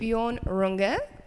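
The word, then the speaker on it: Björn Runge: Bjorn Runge.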